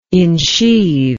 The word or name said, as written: insheathe